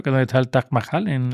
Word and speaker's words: Taj Mahal: ¿Conocéis el Tal Mahal? Sí, claro. Taj Mahal, que no lo pronuncio, He dicho Tal Mahal y tal no es, es Mahal.